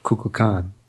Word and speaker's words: Kukulkan: Which, that's how you pronounce it, Kukulkan.